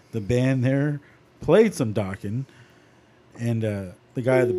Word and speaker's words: Dokken: You're docking, actually. Man. Dokken. – Please tell me I'm not mispronouncing it because Dokken sounds stupid. – No, it's Dokken.